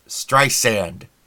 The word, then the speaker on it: Streisand: Streisand. Like sand.